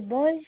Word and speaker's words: Boyes: Le nom c'est Boys et le prénom c'est Paul. – D'accord. C'est quoi? Comment vous l'écrivez votre nom de famille? – Le nom c'est Boys, ça s'écrit Boyes.